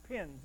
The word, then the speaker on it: penes: He could have used a word that is pronounced pens. It's spelled actually P-E-N-E-S. We would pronounce it P-E-E-N-Z, I'm told.